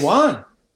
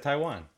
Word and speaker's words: Taijuan: Taijuan. – Yai, well, I pronounced his name wrong. I said Taiwan.